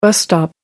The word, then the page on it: bus stop: American English